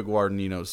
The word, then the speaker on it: Guadagnino: I'm thinking here Luca Guadagnino's Suspiria remake. – Good pronunciation, by the way.